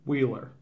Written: Wheeler

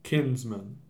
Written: Kinsman

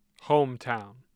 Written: Hometown

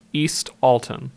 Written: East Alton

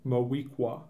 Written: Moweaqua

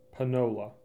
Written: Panola

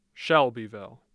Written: Shelbyville

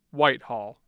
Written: White Hall

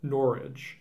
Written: Norridge

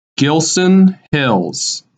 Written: Gilson Hills

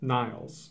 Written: Niles

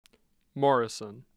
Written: morrison

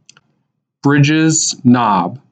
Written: Bridges Knob

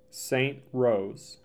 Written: St. Rose

